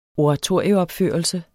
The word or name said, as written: oratorieopførelse